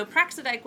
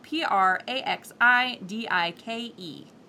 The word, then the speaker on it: Praxidike: Um, so Praxidike was the one who made sure that those punishments uh, were and that vengeance was properly enacted.